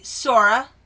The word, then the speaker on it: Saoirse: I've known a Sora, which has a lot of additional letters in it that we're not pronouncing.